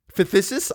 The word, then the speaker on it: phthisis: P-H-T-H-I-S-I-S. Phthisis? Phthisis? I'm looking that up because I don't think that's a real word. P-H-T-H-I-S-I-S.